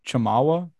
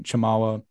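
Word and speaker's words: Tchamwa: They have Jonathan, might butcher this name, Chamawa. I think the T is silent. Jonathan Chamawa, Matthew Mayer, and then of course James Akinjo, who have all become staple leaders of this team.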